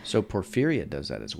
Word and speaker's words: porphyria: Porphyria? P-O-R-P-H-Y-R-I-A. How do you say that? – P-O-R-P-H. Porphyria? – Y-R-I-A.